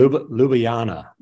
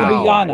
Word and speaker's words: Ljubljana: Now he is in Ljubljana, Slovenia. Did I pronounce that correctly? – Ljubljana. The J's were Ljubljana. Wow.